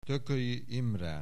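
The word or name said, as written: Thököly Imre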